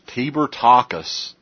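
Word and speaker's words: Tibor Takács: This is Tibor Takacs.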